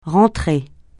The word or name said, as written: rentrer